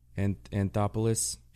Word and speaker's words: Anthopoulos: Anthropoulos, is that right? Alex Anthropoulos? – That's a world champion. – Anthopoulos.